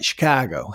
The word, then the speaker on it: Chicago: I even said it right, Chicago.